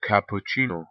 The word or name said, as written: Cappuccino